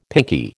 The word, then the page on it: pinkie: American English